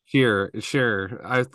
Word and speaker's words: Sherer: Actually, Sheer.